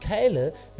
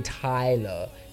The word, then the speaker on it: Kyla: I'm going to play you the one and only Drake and the one and only Wizkid and Kyla, but see one dance. So, Lady Enchant, if you get you heard me, otherwise I didn't say Tyler, Lady Enchant. I said Kyla. Yes, that's how it's pronounced.